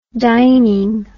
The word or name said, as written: dining